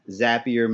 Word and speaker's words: Zapier: And first of all, just thank you for giving me the phrase Zapier makes your life happier, because now I know exactly how to pronounce it, so I really appreciate it.